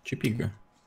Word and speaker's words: Чепига: Чепига, на И. Чепига.